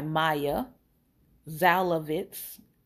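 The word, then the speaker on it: Maia Szalavitz: Born for Love, Why Empathy is Essential and Endangered by Maya Zalovitz. I'll spell that for you. M-A-I-A. And her last name is S-Z-A-L-A-V-I-T-Z.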